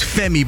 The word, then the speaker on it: F3MIII: His name's Femi, but it's spelled F3MIII, just to be a bit difficult, but he's being a bit original.